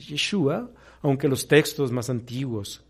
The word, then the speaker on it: Yeshua: Yeshua, vamos a darle el acento en la última A, Yeshua,